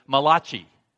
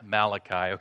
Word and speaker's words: Malachi: So today we're in the final book of the Old Testament, written by the only Italian prophet, Malachi. Just kidding. It's pronounced Malachi, okay?